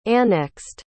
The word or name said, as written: annexed